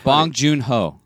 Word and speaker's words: Bong Joon-ho: Bong Joon-ho. – Bong Joon-ho. Oh, what did I say? – Bong Ho-Joon. – Okay. – Bong Joon-ho.